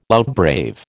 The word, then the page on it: outbrave: American English